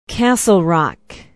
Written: castle rock

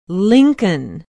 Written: lincoln

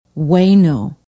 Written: waino

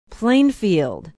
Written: Plainfield

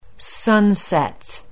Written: sunset

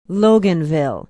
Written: loganville

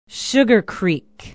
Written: sugar creek

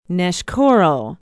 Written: neshkoro

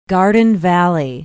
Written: Garden Valley

